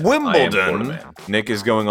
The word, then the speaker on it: Wimbledon: Wimbledon? – Yes. – Well, I know a lot of people say like Wimbledon. It's like it's Wimbledon, D-O-N.